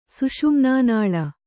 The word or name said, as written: ಸುಷುಮ್ನಾನಾಳ